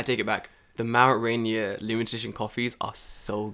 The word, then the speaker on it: Rainier: There's a kind of coffee here called Mount Rainier. Am I pronouncing that right? – Yes. Mount Rainier.